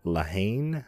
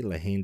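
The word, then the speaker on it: La Haine: Crime drama, they have Lahaine, I believe that's how it's pronounced. L-A and then H-A-I-N-E. Lahaine, directed by Matthew Kasovitz.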